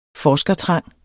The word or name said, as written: forskertrang